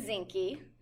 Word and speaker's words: Zinke: So my maiden name is Zinke. – Spell it for us. – Z-I-N-K-E. – Z-I-N-K-E.